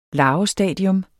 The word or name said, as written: larvestadium